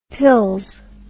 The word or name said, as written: pils